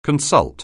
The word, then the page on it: consult: anglais